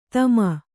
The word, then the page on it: ತಮ: Kannada